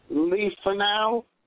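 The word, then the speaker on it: Le Fanu: And those are loosely based on the novella Carmella by, and I might say this wrong, J. Sheridan Leifanau. Is that right? – Right. – Sounds right? Okay.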